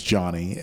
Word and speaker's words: Dsane: My last name is Dsane.